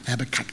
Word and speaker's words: Habakkuk: I got up early this morning to learn how to pronounce that word, actually, Habakkuk. Let me say it five times, Habakkuk, Habakkuk.